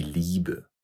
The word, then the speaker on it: Liebe: I, E. Эти две буквы вместе создают долгий звук «и» – «die Liebe» – «любовь».